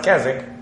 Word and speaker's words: Keswick: Spelled Keswick, but it's pronounced Keswick.